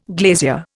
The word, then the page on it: glazier: Indian